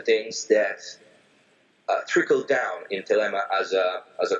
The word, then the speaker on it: Thelema: First of all, Telema, which I pronounce Telema, it's a Greek word, and I would say my pronunciation is ancient Greek,